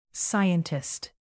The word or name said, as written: scientist